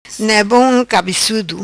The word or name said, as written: Ne Bom Cabecudo